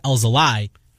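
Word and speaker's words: Alzolay: According to the Cubs, the official pronunciation of Alzo lie, and it came from a tweet from Casper. – Did it? – He had it put out phonetically with lie at the end.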